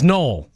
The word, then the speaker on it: Noel: Yesterday, we were talking about different spellings of names and how your mom pronounces your name is probably the way it's supposed to be pronounced, no matter how it's spelled, right? – Because your middle name is spelled Noel.